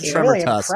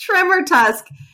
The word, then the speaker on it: Tremortusk: I think it's Tremortusk. Really, yes. – Is it? Tremortusk. I'm sorry. That is definitely that. I'm an idiot. Tremortusk. – I liked your version. – Tremortusk.